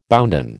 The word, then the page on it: bounden: American English